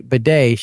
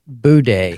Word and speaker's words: bidet: Except she she didn't say bidet; she pronounced it boudet.